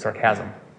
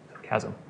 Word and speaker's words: sarcasm: This is not a word that carries with it sarcasm. Sarcasm. Emphasis on the wrong syllable. Sarcasm. Sarcasm, all right?